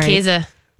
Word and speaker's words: Käse: The smoked, the Casa Thirst. I hope I'm saying that right. – Yeah, Käse. Because it has an umlaut.